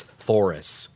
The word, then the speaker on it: Thoris: Her name was Thoris. Rhymes with Doris.